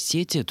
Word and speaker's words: сети: Классическим ударением является «в сети», то есть считается, что это существительное, которое относится к разряду тех, которые, во всяком случае, в единственном числе не меняют ударение. Если в именительном падеже, там у нас, собственно, выхода никакого нет, ударение падает на первый слог, то и во всех остальных формах единственного числа тоже ударение сохраняется.